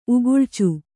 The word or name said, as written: ಉಗುಳ್ಚು